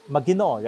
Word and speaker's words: maginoo: Yeah, maginoo, right? That's how you pronounce it.